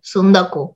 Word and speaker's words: Tsundoku: Se escribe T-S-U-N-D-O-K-U, Sundoku.